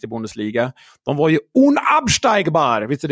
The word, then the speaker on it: unabsteigbar: Det var perfekt uttal. Unabsteigbar! Unabsteigbar, precis.